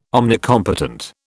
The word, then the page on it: omnicompetent: British English